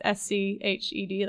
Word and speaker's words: Sched: It's SCHED, like schedule, .org.